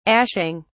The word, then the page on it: ashing: British English